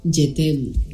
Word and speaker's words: Je t'aime: Se escribe Jaime. Je t'aime. Bueno, hay una T en medio, pero... Sí. Pero digamos, o sea... Básicamente te estás poniendo Jaime.